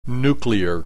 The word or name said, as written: nuclear